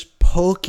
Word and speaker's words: poke: Another item that you have to try is poke. It's spelled P-O-K-E.